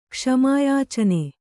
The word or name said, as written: ಕ್ಷಮಾಯಾಚನೆ